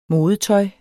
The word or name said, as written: modetøj